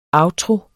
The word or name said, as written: outtro